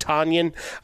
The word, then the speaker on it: Tonyan: Robert Tonyan, not to be confused with Tonyan. It rhymes with Funyan, as we've been told.